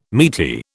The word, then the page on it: meaty: British English